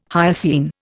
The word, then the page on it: hyoscine: Indian